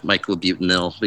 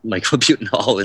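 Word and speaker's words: myclobutanil: It was kind of funny too, like, correcting the ag commissioner on the pronunciation of Michelobutanil, because he kept saying Michelobutanol.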